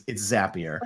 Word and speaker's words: Zapier: It's pronounced as it's a zap. You're creating zaps. So it's Zapier.